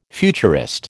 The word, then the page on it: futurist: American English